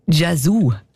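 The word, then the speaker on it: Yazoo: Und der Song «Don't Go» von Vince Clarke und Alison Moyet, alias «Jesus». Ich habe mich immer gefragt, ob ich das richtig ausspreche oder nicht. «Jesus». – Jesu. – Gell? Jesu. Ja. Das ist richtig.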